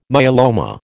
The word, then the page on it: myeloma: American English